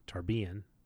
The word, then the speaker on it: Tarbean: And when we get a little bit later on, you know, he's been in Tarbian See, I said it right. He's been in Tarbiyan for almost 3 years, and he's managed to collect like 27 pennies.